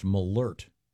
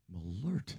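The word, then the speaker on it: Malört: And this says it's pronounced Malort. – Malort. – There's an umlaut, the two dots over the O? This has a pronouncer saying Malort.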